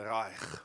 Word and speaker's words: Reich: He kind of was a bit of a thorn in the flesh of the Reich. Did I say that correctly? That's my G, if you were wondering. It is.